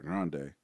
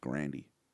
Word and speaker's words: Grande: No, but everybody calls Ariana Grande Ariana Grande. Her last name is pronounced Grandy.